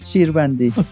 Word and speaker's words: Shirbandi: And I'll let you say your last name, Hamed. – Hamed Shirvandi.